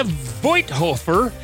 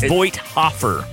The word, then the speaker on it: Voithofer: Hey, we're going to get to Jeremiah Voithofer. And did I say that right? – No, not even close. – Hey. – It's Voithofer.